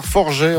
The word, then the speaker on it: Forget: On est ensemble jusqu'à 18h, Forgé en Saskatchewan. Ça s'écrit F-O-R-G-E-T, ça peut se prononcer Forget si on est anglophone, qu'on ne connaît pas la région.